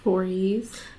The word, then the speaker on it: Voorhees: Voorhees.